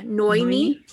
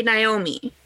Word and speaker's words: Noemi: So the way they said it in the audiobook is it sounds like the ending to Annoy. So Noy, and then Noy. Me. Yeah, Noemi. Is how the audiobook pronounced it.